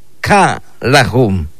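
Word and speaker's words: ka lajun: Ka lajum. Ka lajum. Es el número 12. Escuche usted la pronunciación. Ka-lajum. Es el número 12.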